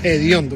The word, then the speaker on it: hediondo: Hediondo, porque en Canarias los antiguos aspiraban la H y la pronunciaban como una G.